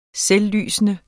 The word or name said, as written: selvlysende